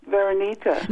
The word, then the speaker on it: Veronica: Veronica.